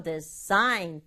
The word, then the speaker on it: design: 영어로 말할 때는 design 여기다가 강조 주는 거예요. 그리고 디자인 세 박자 아니고 design 두 박자로 발음해요.